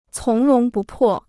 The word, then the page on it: 从容不迫: cóng róng bù pò